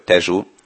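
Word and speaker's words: Tejo: Érdekes egyébként, hogy ugye úgy írják a folyó nevét, hogy Tejó, és portugálul úgy ejtik, hogy Tejú, Portót pedig Portunak, tehát a szóvégi O helyett U ejtendő.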